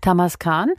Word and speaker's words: Tamaskan: Es gibt ja noch den Tamasken oder Tamaskan. Wie spricht man den aus? – Tamaskan.